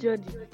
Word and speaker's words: Jodi: My name is Jody. – Jody. – Yes. – Spell it for me. – J-O-D-I. – Oh, J-O-D-I.